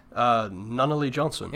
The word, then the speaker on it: Nunnally Johnson: Nunnally Johnson. – Yeah, Nunnally. Okay, Nunnally Johnson. – Well, you've mentioned before that you're a big fan of The Dirty Dozen. – Yeah. – Nunnally Johnson wrote that.